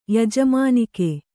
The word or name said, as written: ಯಜಮಾನಿಕೆ